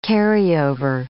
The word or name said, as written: carryover